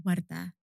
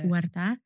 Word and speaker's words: Warda: And in response to your first question, my name is pronounced Warda. That is how my mum says it. – Yeah. – But people who speak English primarily have a very difficult time pronouncing that rolling R. – Yeah. – So it's Warda.